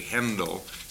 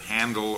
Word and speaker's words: Händel: The German version of his name was H-A with an umlaut. And so if you want to pronounce it correctly, you say Händel instead of Handel or Handel.